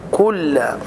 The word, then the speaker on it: كل: الذي إخراج طرف اللسان في الذال، وهذا خطأ شائع، يقولون الذي، أنطق كل مراعاة الحرف المشدد عند النطق، وأن كل كلها مرققة لكن اللام مشددة، يعني الحرف المشدد ينطق مرتين.